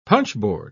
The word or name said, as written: punchboard